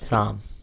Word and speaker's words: Psalm: Psalm. – Is it Psalm? So the P is silent.